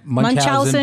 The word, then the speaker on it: Munchausen: Uh, Munchausen by proxy.